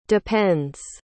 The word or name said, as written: depends